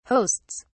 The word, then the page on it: hosts: British English